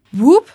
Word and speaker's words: WOOP: Das schreibt man W-O-O-P. Whoop. Das ist eine Methode, mit der man Ziele stressfrei erreichen kann. Um herauszufinden, wie das geht, hat Melissa mit Lara gesprochen. – Whoop, das klingt doch etwas lustig für eine psychologische Methode, die auf 20 Jahren wissenschaftlicher Forschung basiert.